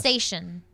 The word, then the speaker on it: cetacean: Cetacean. – Yeah, I just don't know how to pronounce it. – What is it? – It's C-E-T-A-C-E-A-N. – It's cetacean. – Cetacean. – Yeah, not a crustacean. A cetacean.